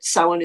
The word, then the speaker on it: Samhain: Uh, the word Samhain, which some people, if you see it spelled, you will probably be tempted to pronounce it Samhain, um, but but it, Samhain is is more appropriate uh, a pronunciation.